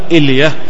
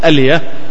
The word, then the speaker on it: ألية: قال لا تقل إلية فإنها خطأ، لا تقل إلية فإنها خطأ. إذن يعني الصواب في نطقها يقال ألية بفتح الهمزة.